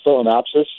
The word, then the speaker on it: Phalaenopsis: I'm a first-time orchid grower, and I had a beautiful, and I'm not even sure how to pronounce it correctly, but Phalaenopsis, is that close? – Yeah, it's very close.